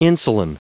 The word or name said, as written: insulin